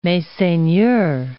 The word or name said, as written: messeigneurs